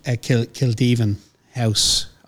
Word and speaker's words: Kildevin House: Okay, and Bridie, talk to me about Kildevin House.